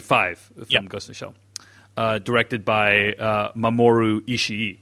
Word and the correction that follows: Oshii: Oshii, sorry.